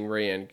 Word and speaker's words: Rian: I gotta keep saying Rian because that's how the name is pronounced.